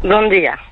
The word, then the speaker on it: Bom dia: Bom dia. – Bien. Bien sûr. Je ne l'ai pas bien prononcé. – Si, si, très bien.